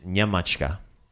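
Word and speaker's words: Njemačka: So, but in Croatian, we do the NJ. So a good example is Nyemachka. – Nyemachka.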